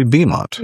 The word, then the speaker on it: Beaumont: I added a U after the A and pronounced it Beaumont, but it's actually Beaumont.